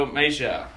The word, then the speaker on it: Mejia: Francisco Mejia, that's how you pronounce it.